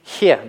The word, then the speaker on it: хе: На произношение это никак не влияет, «хе» есть «хе», «хе» произносится как легкое придыхание.